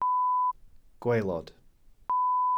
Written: gwaelod

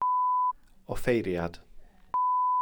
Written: offeiriad

